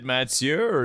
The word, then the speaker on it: Mathieu: Mathieu Pavon. I don't know why I said a French like that, but that's how. – Is it Mathieu? – It is Mathieu.